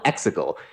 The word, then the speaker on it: Exegol: But no, it's spelled Exegol. It's E-X-E-G-O-L. – G-O-L. You know, G-E-L would make more people would pronounce it Exegol.